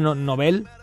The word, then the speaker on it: Nobel: Que tenemos premio Nobel de la paz y de literatura a cosas que se han metido, economía, se ha metido después, y ya Nobel, que se pronuncia Nobel, pues no tiene nada que decir sobre esto.